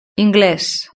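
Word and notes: ingles: Spanish (Spain)

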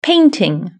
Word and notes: painting: British English

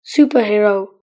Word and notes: superhero: British English

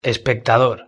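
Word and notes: espectador: Spanish (Spain)

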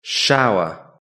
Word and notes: shower: British English

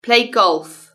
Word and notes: play golf: British English

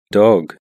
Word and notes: dog: British English

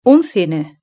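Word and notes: un cine: Spanish (Spain)

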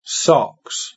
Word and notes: socks: British English

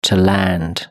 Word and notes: to land: British English

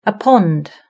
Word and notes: a pond: British English

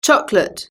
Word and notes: chocolate: British English

